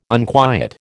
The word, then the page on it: unquiet: American English